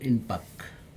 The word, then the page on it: inpak: Aneityum